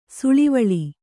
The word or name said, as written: ಸೂಳೆವಳ್ಳ